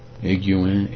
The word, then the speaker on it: Higuain: H-I-G-U-A-I-N, Higuain. I guess the H is silent, and the G-U-A-I-N, 3 vowels in a row, is we don't normally run across that particular combination in English.